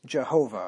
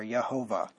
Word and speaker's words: Jehovah: And it's pronounced Jehovah or Yehovah.